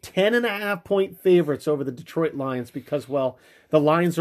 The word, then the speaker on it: Detroit: It's Detroit, not Detroit.